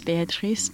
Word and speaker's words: Béatrice: She, would you say her name, Béatrice, like, would you say it in the French pronunciation? – Yeah, her name is Béatrice de Clerval.